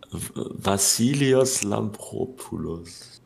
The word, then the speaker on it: Vassilios Lampropoulos: Vassilios Lampropoulos. – Okay, das ist gut ausgesprochen.